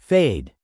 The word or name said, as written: fade